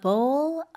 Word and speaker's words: bowl: 네, 이 bowl 자체가 그릇을 얘기를 하는데 이거 발음할 때 쌤, 그 공 할 때 B-A-L-L 이랑은 다르죠? – 네, 맞아요. A bowl. 약간 입술을 더 많이 사용해야 돼요.